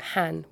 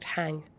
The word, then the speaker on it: hang: I corrected you last week and said you pronounced the instrument han with no G, silent G, and I'm wrong. It is, in fact, hang.